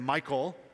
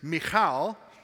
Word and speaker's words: Michal: And so I was going to say her name Michael or Michelle or Mitchell, and none of those are correct. So I was looking this up, and the Hebrew actual way to communicate or pronounce her word is Michal. You got to really get guttural in that cha.